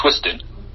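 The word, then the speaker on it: Twiztid: Well, not they, but there's also a spin-off group that's part of their posse called Twisted. If you look them up, it's spelled with a Z. So T-W-I-Z. – Twisted? – T-W-I-Z-T-D or something like that.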